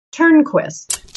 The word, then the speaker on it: Turnquist: Cindy, how do you pronounce your last name, Cindy? – Turnquist. – Turnquist. So it's pronounced just the way it's spelled.